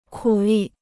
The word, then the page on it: 苦力: kǔ lì